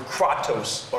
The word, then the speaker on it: kratos: E-N-K-R-A-T-E-I-A. E-N-K-R-A-T-E-I-A. The root word of this Greek word is the Greek word kratos, or K-R-A-T-O-S. K-R-A-T-O-S, kratos, which means power or control.